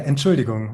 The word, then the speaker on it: Entschuldigung: and I say Entschuldigen, is that right? What is it? How do you say excuse me? – Yeah, Entschuldigung, right.